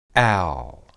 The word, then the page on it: al: Hebrew